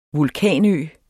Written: vulkanø

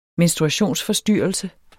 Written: menstruationsforstyrrelse

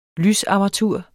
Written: lysarmatur

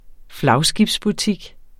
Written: flagskibsbutik

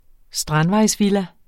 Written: strandvejsvilla

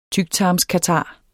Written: TYKTARMSKATAR

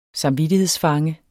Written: samvittighedsfange

